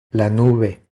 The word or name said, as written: La nube